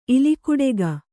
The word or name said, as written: ಇಲಿಕುಡೆಗ